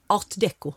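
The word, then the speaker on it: Art déco: Vad är ADK, förlåt? – Art Deco. Förlåt att jag snubbade till mig med det franska uttalet.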